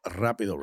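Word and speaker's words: rápido: We're doing a little rapido reaction. – All right. Okay. You got to roll your R's if you want to be a part of this segment.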